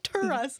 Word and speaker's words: Taurus: um, Taurus. Terrace. How do you say it? Taurus. Taurus. Taurus.